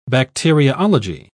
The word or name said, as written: bacteriology